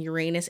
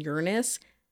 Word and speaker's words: Uranus: you know that I switch between saying Uranus and Uranus almost every single episode, sometimes during an episode.